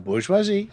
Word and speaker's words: bourgeoisie: In capitalism, this manifests itself in conflict between the ruling class, known as the bourgeoisie, is that a good pronunciation? – La bourgeoisie, yes, good.